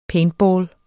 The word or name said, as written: paintball